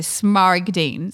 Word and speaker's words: smaragdine: Smaragdine? – There's a G. So smaragdine.